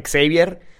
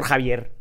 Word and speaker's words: Xavier: Era como el profesor Xavier, que me viene enterando que se pronunciaba Xavier... Por las pinches películas de los X-Men. Porque yo le decía al profesor Javier.